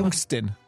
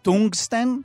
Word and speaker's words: טונגסטן: היה כימאי שחקר בעיקר את המתכת טונגסטן, המתכת שיש לנו בנורת להק. – די. איך מבטאים באמת את המילה הזאת? תמיד היא כתובה ואנחנו לא יודעים. – טונגסטן. – טונגסטן? כן. אוקיי, חוט הלהט שלנו עשוי מהמתכת הזו.